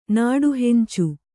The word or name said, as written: ನಾಡುಹೆಂಚು